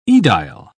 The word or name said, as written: aedile